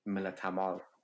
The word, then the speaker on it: Millatmal: And then I have Alexandra Millatmal. Alexandra, am I pronouncing that last name right? – It's Millatmal. – Okay, sorry. I totally try to like Afghanize it. Millatmal.